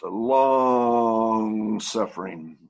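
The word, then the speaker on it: long-suffering: Still remember dear brother Dick Gorgas speaking on this, and he said, we don't pronounce that very well. That should be pronounced long suffering.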